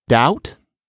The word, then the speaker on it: doubt: Doubt? Nobody says doubt, but that B is in there because somebody thought, well, you know, dubitare, etc.